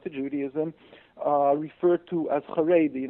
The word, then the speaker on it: Haredi: it's Haredi, is that how you say it? – Haredi, they say God-fearing, yes.